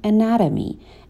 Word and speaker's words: anatomy: So, the cost of cutting the wrong blood vessel makes a surgeon master human anatomy. Anatomy همون آناتومی هستش. تلفظ صحیحش رو یاد بگیریم. Anatomy.